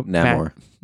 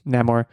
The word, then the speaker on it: Namor: Is it Namor? I don't know how to pronounce that one. Magneto. – Namor. – Namor.